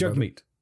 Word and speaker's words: Jagmeet: Jagmeet.